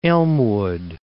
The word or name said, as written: Elmwood Park